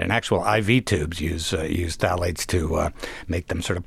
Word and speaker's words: phthalates: Yeah, this is an incredible substance called phthalates.